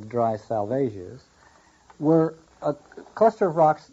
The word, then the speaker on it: Salvages: The three savages, a small group of rocks with a beacon, and then we find out that salvages rhymes with assuages.